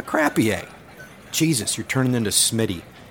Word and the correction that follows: croupier: It's pronounced croupier."